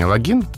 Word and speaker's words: логин: Словари русского языка дают ударение «логин».